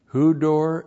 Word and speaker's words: hudor: Hudor, H-U-D, long O-R. Hudor, that's the Greek word water. That's water and haima. H-A-I-M-A, haima, that's the Greek word blood.